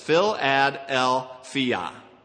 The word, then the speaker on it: Philadelphia: And I looked it up in Strong's, and you pronounce it like this. Phil-ad-el-phia.